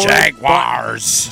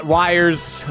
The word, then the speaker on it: Jaguars: Some people aren't getting up for Jaguars. – Jaguars, not wires. I hate that pronunciation.